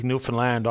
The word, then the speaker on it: Newfoundland: So I'm throwing an idea out for the government and anybody else who'd like to comment on it, that we take the O out of the found part of Newfoundland and F-U-N-D, Newfoundland. That's the way we pronounce it.